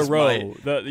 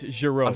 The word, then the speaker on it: Jarreau: I mean, it's that's not Jarreau. You're doing it with a hard J. It's Jarreau.